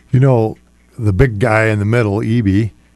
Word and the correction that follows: Edey: I think that's right pronunciation Edey. – His name, Edey.